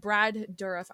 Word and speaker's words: Brad Dourif: That Brad Dourif, I think I said that right. – Yep. – Right? Brad Dourif.